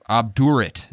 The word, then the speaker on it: obdurate: obdurate. Is that the proper way to say that? – Yep, that's right.